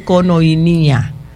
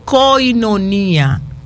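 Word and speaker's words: Koinonía: No se dice Conoinía. Coinonía. Coinonía de compartir, de mutuo acuerdo, de disfrutar. Eso es Coinonía. Conoinía no la conocía hasta el día que la escuché.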